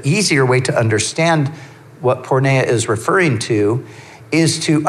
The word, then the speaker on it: porneia: So sexual immorality is the translation of a Greek word that is pronounced porneia.